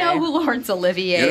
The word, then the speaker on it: Olivier: Olivier, not Oliver.